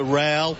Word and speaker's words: Rowell: Yes, that's the Wits to Rowell. Rhymes with Powell, who got the ball away to Anderson, and the Suns are at full forward.